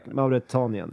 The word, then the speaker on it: Mauretanien: Jag sa, jag, sa, till en riktig forskare igår att eh, Mauritanien, heter, uttalar man ens Mauritanien, landet i norra Afrika? Mauretanien. ja jag säger så.